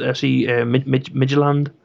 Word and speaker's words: Midtjylland: FC Michelin. – Midgeland. – How do I pronounce it? Educate me. – I think it's pronounced FC Midgeland.